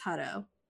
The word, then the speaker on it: Hutto: I think it's Hutto. Yeah, it's Hutto. If you say it without pronouncing all the T's.